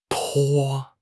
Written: 颇

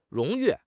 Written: 荣誉

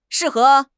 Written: shi4 he2

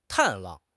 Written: 探望

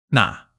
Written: na3

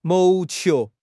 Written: 谋求

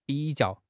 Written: bi3 jiao4

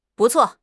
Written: bu4 cuo4

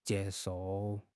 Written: jie1 shou4

